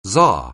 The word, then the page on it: czar: anglais